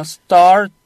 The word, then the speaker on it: start: 그래서 R 다음에 T는 날린다 그랬으니까 그냥 Start the test.